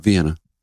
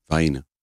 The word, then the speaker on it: Vienna: Not Vienna. Vienna. We don't pronounce these towns exactly the way they would be pronounced where they are.